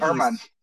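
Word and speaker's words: Germán: Erman. – German, sorry. That's silent A or silent G. Get you every time. – Well, there's supposed to be the accent over the A too.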